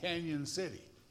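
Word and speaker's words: Cañon City: It's called Canyon City. But it was spelled with one N, but pronounced Canyon in Mexican or in Spanish. Not talking about that type of C-A-N-O-N.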